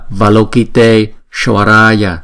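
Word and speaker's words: Avalokiteśvarāya: Nhưng khi chúng ta đọc ghép liền với nhau á, thì chúng ta đọc là Tesh, Tesh, thay vì là T. Khi chúng ta đọc là Valokite, Shvaraya, thì nó thành hai chữ, nó tách ra thì nó không hay lắm. Cho nên chúng ta phải đọc cho nó gom lại với nhau.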